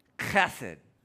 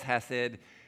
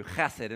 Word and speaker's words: chesed: It reads from right to left, and then I have the different ways it gets spelled in English, but it's actually pronounced chesed. You want to say that with me? Chesed. It's a hard H. So sometimes you read it as hesed, but it's a hard H in Hebrew, chesed, and so you'll see the CH.